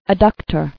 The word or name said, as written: Adductor